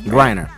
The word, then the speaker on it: Griner: Brittany Griner. I said that right. – Griner. Yeah.